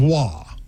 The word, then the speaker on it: Roy: There's no R. – Why do you keep throwing in an R? – But there's an R in the name, though, right? – Yeah, R-O-Y, but it's pronounced Wah.